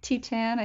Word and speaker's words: Titane: I like Titane. I think that's the correct mm-hmm. way of saying it.